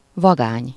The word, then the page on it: vagány: /ˈvɒɡaːɲ/